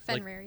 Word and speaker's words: Fenrir: So, in the comics, Hela and, I always say it wrong, Fenrir? – Fenrir.